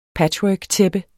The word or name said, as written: patchworktæppe